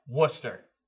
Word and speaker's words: Worcester: Worcester. – I'll go pee. – Correct. Yeah. – Or what? – Worcester.